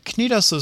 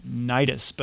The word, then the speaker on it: Cnidos: You might also come across the pronunciation Cnidos, but that's just plain silly.